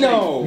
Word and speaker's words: Mano: Mano. – Mano? – Just think about it, M-A-N-O. Just Mano. Act like the I ain't there. No I in Mano.